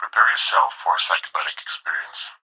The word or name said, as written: Prepare Yourself